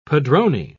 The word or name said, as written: padrone